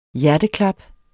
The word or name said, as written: hjerteklap